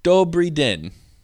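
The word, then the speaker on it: dobrý den: Or something, you know, like, dobry den. – What is it? – It means good day. It's a formal, it's like a more cheerful greeting. – Wait, how do you say it? – Dobry den.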